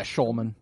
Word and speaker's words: Schulman: Yeah, Schulman.